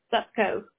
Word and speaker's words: Supcoe: Supcoe. S-U-P-C-O-E.